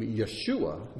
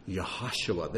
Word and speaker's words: Yeshua: Now, there's some people around, and God bless them, it's okay, but they insist on pronouncing Yeshua's name Yahashua. They want the H in there because it just seems like it should be.